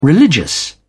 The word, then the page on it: religious: American English